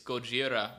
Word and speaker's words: Gojira: It's Gojira, which is the Japanese pronunciation, and they like really bastardized it when they pronounced or translated it to English or anglicized it or whatever.